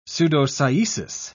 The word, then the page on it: pseudocyesis: British English